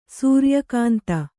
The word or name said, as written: ಸೂರ್ಯಕಾಂತ